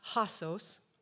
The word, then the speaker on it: hosos: This word, pronounced hosos, is used 115 times in the New Testament.